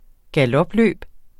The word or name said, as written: galopløb